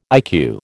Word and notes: IQ: American English

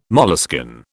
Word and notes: moleskin: British English

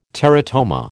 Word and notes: teratoma: American English

